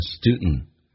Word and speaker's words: student: 我们来看一个单词叫 student，在美语当中 D 跟 N 组合在一起， D 可以不用发音，那么同时呢气流阻塞一下变成 student student student 跟 N 有一个零 NT 组合，一般用 N 进行连音，所以这个叫做 college student college student，所以变成 and that you're also college student in Canada。